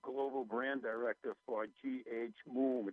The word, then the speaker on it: Mumm: Moom Champagne Chef de Cave and Stella Master.